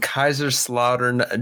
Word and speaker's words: Kaiserslautern: So yeah, I grew up in Kaiserslautern, Germany.